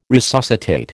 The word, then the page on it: resuscitate: American English